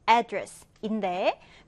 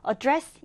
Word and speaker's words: address: 자, 이 단어는 일단은 명사로 쓰일 때 기본적으로 첫 음절의 강세 해서 address인데 그런데 이제 발음상의 편의를 위해서 뒤음절의 강세를 두기도 합니다. address 이렇게 말하기도 해요.